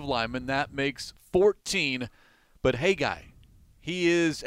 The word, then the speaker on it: Haggai: Hey, guy. That's how he pronounces his first name, which is spelled H-A-G-G-A-I. Hey, guy.